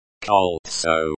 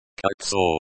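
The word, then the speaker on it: Koltso (кольцо): Koltso. Is the noun, ring, and the subject of the sentence. Translation. On her finger was a beautiful golden ring. Pronunciation. Koltso. Koltso. Colon, koltso, emphasis on the first syllable.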